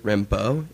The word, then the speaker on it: Rimbaud: But this was inspired by Arthur Rimbaud. Is that how you pronounce it? Right? Okay, great.